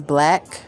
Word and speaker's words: Blacc: So I'm going to spell that out for you because people just swear they know how to spell black. But I spell it differently. So first name, Nakita. That's N-A-K-I-T-A. The next is Black, B-L-A-C-C.